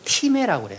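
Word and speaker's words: 티메라고 (τιμή): 그리스 말로는 티메라고 그래요. 타임이라고 쓰고 티메라고 읽는데 그 존경심 무엇인가 압도되는 느낌 이런 것이 생겨요.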